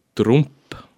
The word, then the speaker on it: Trump: Ma üldiselt sellise asja puhul, ma küll hoian silma peal uudistel, põhilistel uudistel, mida teeb Donald ja nüüd ma hääldan nimme Trump, sest ta on Saksa päritolu, või mida teeb Putin või mida teeb Assad või Jüri Rataski.